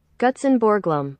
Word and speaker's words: Gutzon Borglum: This is his name Gutzon Borglum. I hope you heard that. Gutzon Borglum.